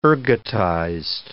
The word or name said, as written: ergotized